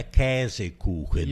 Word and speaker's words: Käsekuchen: Pronúncia correta é Käsekuchen.